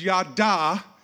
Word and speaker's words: Yadah: Everybody say Yadah.